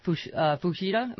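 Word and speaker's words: Fujita: That's the organization working to end homophobia in sports, because we should also mention Scott Fushida. Am I saying that right? Mm-hmm. Of the New Orleans Saints, along with...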